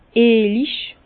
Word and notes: eelig: Bas-Rhin; Herrlisheim

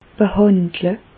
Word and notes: behàndle: Bas-Rhin; Reichshoffen

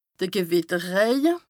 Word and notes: de Gewìtterrëje: Bas-Rhin; Strasbourg

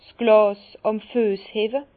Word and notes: 's Glàs àm Füess hewwe: Bas-Rhin; Herrlisheim

